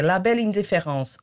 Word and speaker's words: la belle indifférence: Mas há uma sonsice ali naquilo que é os franceses chamam a estes traços histéricos, tem um nome genial, que é la belle indifférence.